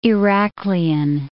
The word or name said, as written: Iraklion